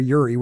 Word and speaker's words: Jurij: And I do not know how to pronounce the first name, J-U-R-I-J. But I'm going to say Jurij. Yeah, Jurij. – Yeah, it's probably there, Yuri, which is George.